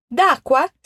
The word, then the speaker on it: d'acqua: D'acqua seria de aqua, mas você pronuncia como se fosse uma palavra única, d'acqua.